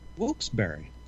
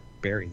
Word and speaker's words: Wilkes-Barre: Wilkes-Barre. – Wilkes-Barre. Spell it. – W-I-L-K-E-S space B-A-R-R-E. – Yes, that's the kick, Barry. There's no E-Y there.